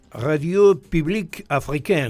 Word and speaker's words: Radio Publique Africaine: Deberías decirlo vos, que tenés una pronunciación del francés mucho más excelsa que la mía. – No, Radio Publique Africaine. – Bueno, exactamente, vos lo dijiste muy bien.